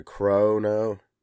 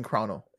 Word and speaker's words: Chrono: Yeah, Chrono. I say it like with a long O, like a crow-no. – Corona. Okay, I'm saying Chrono.